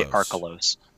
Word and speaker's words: Archelos: but is very very good is Archelos? Did I say that right? Archelos. – Archelos. I, I, I would say Archelos.